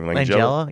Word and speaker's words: Langella: Just made by Frank Langella. – God damn it! – Langella. – Yeah. – What's that? How do you pronounce his name? – Langella.